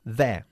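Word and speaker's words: they're: Te recuerdo la pronunciación por enésima vez de la contracción they are. There. Una sílaba. – There. – Bien. There. – There. – Pero saca la lengua.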